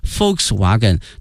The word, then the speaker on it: Volkswagen: 首先看一下大众、啊、 VW，它的全称呢是 Volkswagen，因为在德语里面这个 Volks、啊、注意一下这个 V、啊、它的发音呢是 F 音、啊、 Volkswagen 它表示人民的意思 people， wagon 呢是车， Volkswagen 这个词德语呢翻译成英语呢是 people's car，翻译成中文呢是大众。